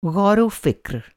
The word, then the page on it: ग़ौर-ओ-फ़िक्र: غور‌ و فکر